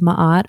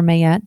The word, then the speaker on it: Maat: It's based off of the 42 ideals of Maat or Mayet, depending on how you want to pronounce it.